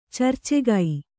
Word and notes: ಚರ್ಚೆಗೈ: carcegai

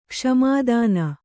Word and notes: ಕ್ಷಮಾದಾನ: kṣamā dāna; Kannada

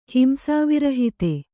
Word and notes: ಹಿಂಸಾವಿರಹಿತೆ: himsāvirahite

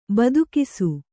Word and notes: ಬೆದಕಿಸು: bedakisu

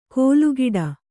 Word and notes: ಕೋಲುಗಿಡ: kōlu giḍa

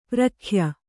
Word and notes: ಪ್ರಖ್ಯ: prakhya

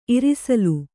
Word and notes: ಇರ್ಸಾಲ್: irsāl

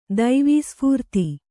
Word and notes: ದೈವೀಸ್ಫೂರ್ತಿ: Kannada

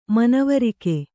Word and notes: ಮನವರಿಕೆ: manavarike

